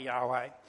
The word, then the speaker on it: Yahweh: People used to pronounce it Jehovah. Most people these days tend to think it was probably Yahweh, but that's what we're referring to.